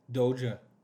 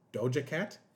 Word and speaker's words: Doja Cat: And that was the first time I ever saw Doge Cat. Or, you know, the person who performed that thriller. Am I saying it right? – Doja. – Doja Cat? – Yeah.